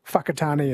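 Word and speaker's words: Whakatāne: I think the WH is pronounced F, like Whakatane is WH.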